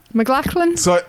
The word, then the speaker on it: MacLachlan: MacLachlan? – MacLachlan, yep, sorry.